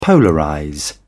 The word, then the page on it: polarize: British English